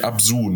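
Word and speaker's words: Abzû: Und ähm, absolut. Ähm, dementsprechend, nee, Absu, nicht absolut, sorry.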